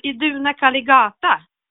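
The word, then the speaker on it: Iduna caligata: om en sån stavelse är, är lång, det är alltså den näst sista stavelsen, om, det, om den är lång, till exempel i duna, Aha. då hamnar betoningen på den. Och just exempel Iduna Kaligata, det, det, det, blir, det är i det här fallet att näst, näst sista stavelsen är lång och då ska vi betona den.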